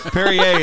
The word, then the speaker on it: Perrier: Perrier is actually pronounced Perrier. – Yeah.